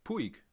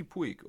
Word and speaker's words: Puig: Wo ist Ricky Puig? Oder wie heißt der? Ricky Puig? Oder Puig? – Ricky Putsch, wird der, glaube ich, ausgesprochen.